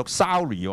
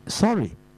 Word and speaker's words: Surrey: 原來咧有人話讀 Surrey 喎，Surrey 嚇，即係咁，但係咧又 Surrey 就肯定錯噶啦。原來咧我上網咧，原來佢仲有趣，原來就係 Sorry。 Sorry，Sorry，即係對唔住，嗰個發音咧就啱噶啦。